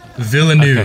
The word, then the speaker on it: Villeneuve: Villeneuve. Okay. – Villeneuve. Oh, I'm going to totally get this wrong. V-I Villeneuve. V-I-L-L. Villain. V-I-L-L-E-N. Neuve. – What? – V-I-L-L-E-N-U-V-E.